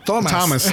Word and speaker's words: Thomas: A Tomás. A Thomas. es, que, como es TH, pues, y, la pronunciación que le hace en el workroom es Thomas y no Tomás.